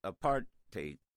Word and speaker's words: apartheid: And this was at the height of the apartheid. I always say it rhymes with hate.